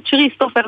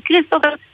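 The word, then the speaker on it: Christopher: שככה רושמים את זה ולא מבטאים את ה-S, וכמו שלמשל כריסטופר, אתה רושם CH, אתה לא אומר צ'ריסטופר, אתה אומר כריסטופר, שום דבר, לא רק שהוא לא אישר את זה. S-E-A-N, e כן.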